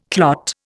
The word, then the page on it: clot: Indian